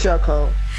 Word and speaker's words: Shacole: It's Shay Cole. – Shay Cole. – How you spell it? Because I'm trying to figure this out. – He said it like Shay Cole, like J. Cole. – Right. – How you spell your name? – S-H-A-C-O-L-E.